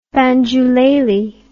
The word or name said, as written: banjulele